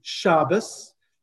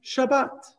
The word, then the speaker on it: Shabbat: But that results in Ashkenazim saying Shabbos and Sephardim saying Shabbat.